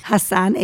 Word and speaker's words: Hassan: We're on Facebook, Siat Yerushalmim in Hebrew, Jerusalemites Party, or find me, Fleur, F-L-E-U-R, Hassan. – Spell it again. – F-L-E-U-R, Fleur, Hassan, H-A-S-S-A-N, and Nahoum, that's my husband's name, N-A-H-O-U-M.